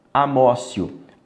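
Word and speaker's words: amotio: Amócio. Amócio com T, tá? Mas aí como é latim, a gente pronuncia com som de C.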